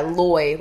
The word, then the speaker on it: lawyer: how do you pronounce the first syllable of lawyer? rhymes with boy, rhymes with flaw. Oh, yeah, loy, like boy.